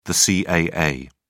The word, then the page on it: caa: British English